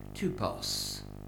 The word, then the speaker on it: Tupos: You haven't got the Greek before you, but if you did, you'd have a little word, and the word is Tupos. T-U-P-O-S. What's that sound like? It sounds like the word type.